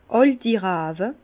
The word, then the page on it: àlti Rawe: Bas-Rhin; Herrlisheim